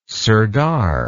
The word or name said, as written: sardar